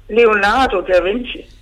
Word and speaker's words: Leonardo da Vinci: Jeg prøver ham at lave Leonardo da Vinci.